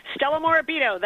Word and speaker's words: Stella Morabito: Uh, Stella, uh, first of all, thank you for joining us, and secondly, did I get that last name pronunciation right? Because I was trying to get it right, and I thought I was doing good, and now I'm not sure if I am or not. – Oh no, no, Tim! Thanks so much for having me on. No, you it, you you pronounce it just fine, perfectly. Stella Morabito, that's right.